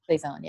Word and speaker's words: Cezanne: Some like the painter Cezanne.